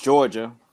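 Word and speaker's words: Jawga: In my world, it's J-A-W-G-A, you know, pronounced Georgia, or if you read it out, Georgia, you know, just a different take on it.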